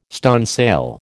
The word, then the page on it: stunsail: American English